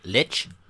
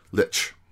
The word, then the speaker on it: lich: Leash. Lich? – Lich. – Laish king? – Laish. Okay. Yeah. It's one of those that I've read a ton but never really heard pronounced. So I think it's a lich.